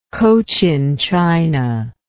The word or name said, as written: cochinchina